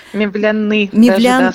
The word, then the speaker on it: Мевляны: Мы говорили уже о мечети Мавзолея Мевляны, правильное ударение, да? – Мевляны, скорее.